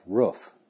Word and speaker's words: roof: The people of some cultures pronounce the word R-O-O-F, roof. And it should be roof. Like poof. Or woof. Or hoof.